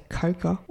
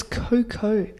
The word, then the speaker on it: cocoa: But Aussie, we don't fucking say coca. Well, and it's cocoa. Well, it's made from coca. But it's pronounced cocoa if it's in a drink.